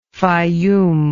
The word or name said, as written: Fayum